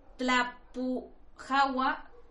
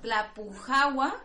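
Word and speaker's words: Tlalpujahua: Tlapujahua, Michoacán. Me costó trabajo, pero ya no la voy a decir otra vez. – Yo no la he podido pronunciar. – ¿No la has podido pronunciar? No. Bueno, Tlapujahua, Michoacán, ya salió mejor.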